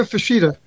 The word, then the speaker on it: Fuchida: Mitsuo Fuchida. – How do you pronounce his first name? – Mitsuo, M-I-T-S-U-O, Mitsuo Fuchida, F-U-C-H-I-D-A.